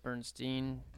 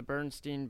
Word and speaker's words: Berenstain: B-E-R-E-N-S-T-A-I-N, Bernstein Bears. I was always under the impression that the A was an E, and it was Bernstein Bears.